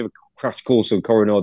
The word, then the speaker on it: Koh-i-Noor: Now, quickly, we mentioned it briefly, but forgive my pronunciation here. Talk to me about, is it the Koh-i-Noor Diamond? – Koh-i-Noor Diamond. – Koh-i-Noor.